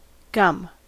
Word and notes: gum: American English

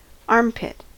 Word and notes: armpit: American English

